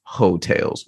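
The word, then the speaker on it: Heaux Tales: I love that Jasmine Sullivan won best R&B album for an album called Ho Tales. Like, it's spelled H-E-A-U-X, but it's still it's pronounced Ho Tales, which is hilarious to me.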